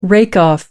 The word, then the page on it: rake off: American English